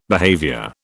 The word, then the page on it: behaviour: British English